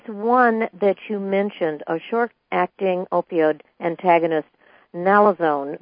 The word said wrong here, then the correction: naloxone: that's used in Naloxone, correct. – Naloxone, yes, excuse me, I mispronounced it, thank you.